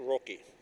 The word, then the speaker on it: Rokke: It's spelled K-J-E-L-L. Last name R-O-K-K-E, Roki.